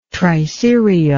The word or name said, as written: triserial